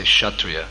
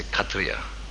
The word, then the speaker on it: Kshatriya: We say Kshatriya. But when Prabhupada would speak, he would say Katriya. In Bengali, they would pronounce it Katriya.